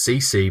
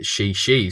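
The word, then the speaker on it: Šiši: It is Šarić, and even has a nickname, which is spelt S-I-S-I, which I would think is C-C, but it's actually She-She. There's plenty of difficult things to bring up here. And yeah, maybe we're both better suited with calling him Dario or the homie or She-She potentially, or Super Dario, I think is his other nickname that gets thrown around.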